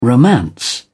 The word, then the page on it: romance: British English